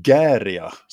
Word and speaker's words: Gaerea: Och jag pratar ju självklart om Portugals Gäria, som jag har lärt mig att det uttalas nu. Mm. Eller det stavas Gaerea, men ja, Gäria. Precis.